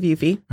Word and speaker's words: Yuffie: Am I saying that correctly? Is it Yuffie? – Yeah, I believe so. – Okay. So, yeah, she was the voice of Yuffie.